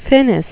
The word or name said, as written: finis